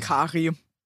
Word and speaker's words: Kary: K-A-R-Y, wie würdest du den Namen aussprechen? Kari oder Carrie? – Kari.